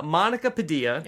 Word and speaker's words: Padilla: We're going to also speak to our guest today, a woman named Monica Padilla.